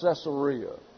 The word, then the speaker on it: Caesarea: But it's pronounced Caesarea.